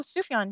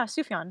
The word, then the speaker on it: Sufjan: Oh, Sufjan. Yeah, Sufjan. – Sufjan.